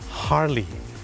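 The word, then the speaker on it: Harley: คนไทยออกเสียงว่าฮาร์เลย์แต่เจ้าของภาษานะครับไม่ได้ออกเสียงว่าฮาร์เลย์ครับเขาออกเสียงว่าฮาร์ลีย์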